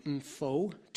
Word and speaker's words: Mpho: I believe you pronounce her name Mpho Tutu.